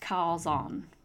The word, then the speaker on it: chazon: the word for it is shazon. It kind of looks like calzon, which sounds like something that makes me hungry right now for like Italian food. C-H-A-Z-O-N. And it's pronounced calzon.